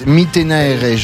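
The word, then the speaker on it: Mittenaere: Iris Mitenaere. Je crois que c'est comme ça que ça se prononce. On me fait signe.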